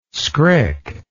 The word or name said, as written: skrik